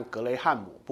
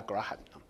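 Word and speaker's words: Graham: 不过他的那个名字的读音比较像格雷汉姆，不过我比较喜欢叫他格拉汉。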